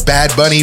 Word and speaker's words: Bad Bunny: Bad Bunny rhymes with money.